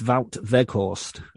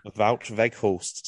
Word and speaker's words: Wout Weghorst: I think it's Wout Weghorst. – Wout Weghorst.